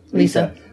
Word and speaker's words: LISA: But in 10 years, we're going to have LISA, how are they pronouncing it? – LISA.